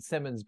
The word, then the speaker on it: Simonds: And I say Simons, I pronounce it Simmons, but you spell it Simons, S-I-M-O-N-D-S.